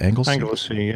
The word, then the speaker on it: Anglesey: Anglesey, yes.